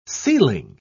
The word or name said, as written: sealing